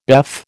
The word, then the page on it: BEF: American English